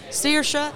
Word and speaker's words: Saoirse: So it's Saoirse, SAOIRSE, and it means freedom in Gaelic.